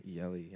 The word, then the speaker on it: Tooele: T O O E L E. Yelly.